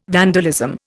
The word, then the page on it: vandalism: Indian